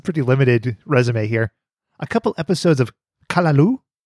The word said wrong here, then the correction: Caillou: It's Caillou. – He wrote Kalaloo. – It's a bad show. – It's spelled C-A-L-I-L-O-U, and it's pronounced Caillou? – Yeah.